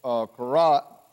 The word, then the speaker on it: Korah: In this 49th chapter of the book of Psalms, and it says it is one of the songs or that Korah is the best way to pronounce it, wrote.